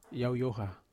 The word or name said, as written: oha